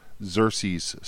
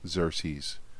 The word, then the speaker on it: Xerces: Another place to look is a Xerces Society. That's spelled with an X, C, Xerces.